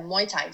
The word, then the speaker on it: Muay Thai: And I have to ask, what is, and I might be saying it wrong, is it Muay Thai? – Yeah, Muay Thai.